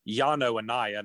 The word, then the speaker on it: Yano Anaya: It's Yano Anaya, not whatever the hell I called him.